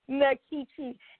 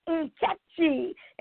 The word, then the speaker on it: Nkechi: And I said, all right, that's how my name, Nkechi. And you know, Joy, it wasn't until I went to college many years later that I found out that the correct pronunciation was Nkechi.